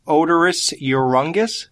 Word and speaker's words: Oderus Urungus: Maybe I'm saying it wrong. Odorous Urungus. – Yeah, that's pretty much it.